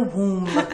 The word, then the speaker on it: womb: In the womb with a silent B.